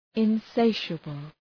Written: insatiable

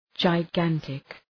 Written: gigantic

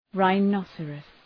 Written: rhinoceros

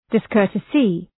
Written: discourtesy

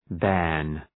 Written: bairn

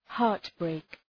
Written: heartbreak

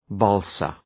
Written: balsa

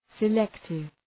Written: selective

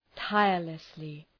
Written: tirelessly

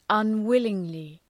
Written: unwillingly

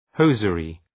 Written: hosiery

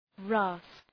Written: rasp

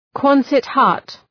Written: Quonset hut

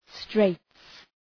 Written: straits

